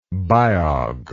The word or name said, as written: biog